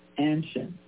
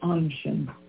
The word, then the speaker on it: Anshin: Well, it's really typically American to pronounce that first A hard, Anshin. But really it's a soft A. It's Anshin.